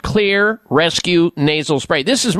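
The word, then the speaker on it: Xlear: And Clear, by the way, is spelled with an X, X-L-E-A-R. The X stands for xylitol.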